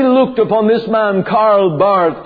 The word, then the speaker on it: Barth: Now, the father of neo-orthodoxy is a man called Karl Barth, or Barth, as he may have been pronounced in the German.